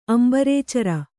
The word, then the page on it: ಅಂಬರೇಚರ: Kannada